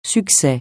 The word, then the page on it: succès: francais